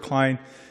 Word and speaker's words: Kline: For the record, my name is Chris Ward Klein. I also go by Sergeant Klein of the United States Marine Corps. The last name is spelled K-L-I-N-E.